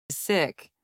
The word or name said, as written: sick